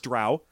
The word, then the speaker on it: drow: So I know it's drow because it says it rhymes with cow. Huh. It doesn't rhyme with co.